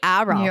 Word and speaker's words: aural: Oral. – Say it right. – Oral. – Not, no. Aural. – Aural. – You're making it worse. – You're very aural. – That's better. Thank you.